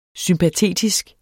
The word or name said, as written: sympatetisk